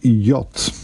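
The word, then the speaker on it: jott: The word yacht, spelled J-O, double T, is the local word for yacht. Okay, pronounced like its English equivalent.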